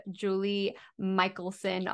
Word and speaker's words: Julie Michelson: I'm super excited to have Julie Michelson on.